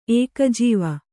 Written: ಏಕಜೀವ

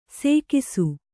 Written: ಸೇಕಿಸು

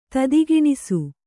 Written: ತುದಿಗಾಣಿಸು

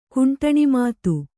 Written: ಕುಂಟಣಿಮಾತು

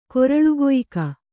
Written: ಕೊರಳುಕೊಯ್ಕ

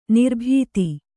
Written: ನಿರಾಬಾಧೆ

